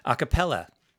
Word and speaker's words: a cappella: Can't say it correctly. A cappella.